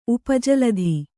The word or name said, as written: ಉಪಜಲಧಿ